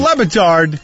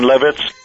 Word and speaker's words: Le Batard: Not Dan Levitard. – Levitz. – Dan Levitz.